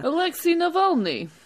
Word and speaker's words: Alexei Navalny: Apologies to any Russian listeners for my incredibly awful pronunciation there. – Alexei Navalny. – Thanks. Can you say that every time it needs saying?